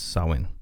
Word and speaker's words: Samhain: It looks like it's Samhain. It's a common mispronunciation because it's of its spelling, but in Celtic it's Samhain.